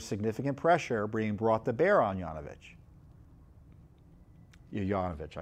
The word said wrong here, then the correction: Yovanovich: I think her name, I keep on pronouncing her, mispronouncing her name. It's Yovanovich, excuse me.